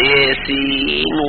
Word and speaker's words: يس: نعم، وأمال بعض القراء الياء، نعم، وبعضهم أدغم النون، نون لفظ السين مع الواو، فقال ياسين و، يعني وضعوا جعل الياء ممالة والسين إنما نون السين، لأن هذه الألفاظ إنما تنطق بأسمائها، لا نقول يس وإنما نقول يا أي يا وسين.